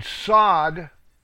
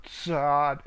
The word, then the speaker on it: Tsadde: Number 18, Sod, is a TS sound. Sod, it's a ts. T-S-A-D-D-E.